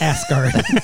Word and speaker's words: Asgard: You're mispronouncing it. – Uh-huh. – It's Asgard.